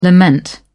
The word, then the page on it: lament: British English